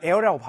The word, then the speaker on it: heir: 그러면서 우리 에러는 eventually 뭐가 되는 거냐면 여기 동사가 생략됐지만 뭐 become 정도 또는 뭐 are 정도가 생략됐다 보시면 our heir 자 지금 에어라고 발음하는데 에어는 어떤 거냐면 상속자.